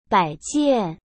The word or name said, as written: baijian